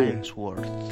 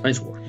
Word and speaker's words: Ainsworth: Esta confesión era una confesión escrita, según se cree, por dos separatistas llamados Francis Johnson y Henry Aishout. No sé si estará bien pronunciado. Ahí damos un cubo. – Ainsworth. – Ainsworth, bueno.